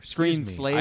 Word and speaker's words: Screen Slaver: Screen Slayer. – Has the most ridiculous name. – Well, okay, I've been Screen Slaver. – Screen Slaver. – I didn't say it right.